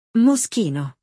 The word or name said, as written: Moschino